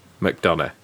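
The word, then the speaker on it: McDonagh: and I think Madonna's done a really good... how do we pronounce his name? – McDonough, right.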